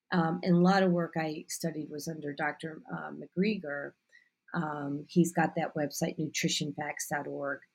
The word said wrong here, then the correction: Greger: Michael Greger? – Yeah. I'd never pronounce his name right.